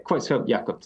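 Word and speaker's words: Creutzfeldt-Jakob: Uh, and it's called variant uh, Creutzfeldt Jakob disease. Did I say that right? – Yeah, Creutzfeldt Jakob disease, that's correct.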